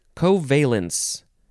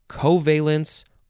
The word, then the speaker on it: covalence: It is covalence. Noun from 1919. You can emphasize the first or the second syllables. Covalence or covalence.